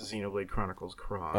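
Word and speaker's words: Xenoblade Chronicles X: It's definitely the letter X, but it's pronounced Xenoblade Chronicles Cross.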